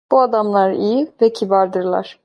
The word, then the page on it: adamlar: Turkish